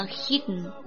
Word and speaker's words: hidden: 后边的 the hidden law，我读的比较长一点哈， hidden 实际上它的发音是 the hidden， hidden 鼻子去发音去了哈，吞掉了 the hidden law，隐藏的规律。